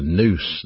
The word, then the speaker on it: nous: In the New Testament, the mind is pronounced in the Greek terminology, the nous, the N-O-U-S. And if it's the heart, it's pronounced cardia, K-A-R-D-I-A, and we get an English word from that, cardiology or cardiac.